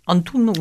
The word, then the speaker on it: Antoun: Voilà, et les chanteurs qui sont donc l'un ténor, c'est Frédéric Antoum. – Antoun. Moi je prononce Antoun.